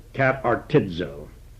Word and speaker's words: katartizo: It is K-A-T-A-R-T-I-Z-O. In Greek, and that's the long O, omega, and it's a verb. And in Greek, when you come upon the letter Z, it's pronounced as if it had a D with a Z. And you would pronounce this katartizo. Katartizo.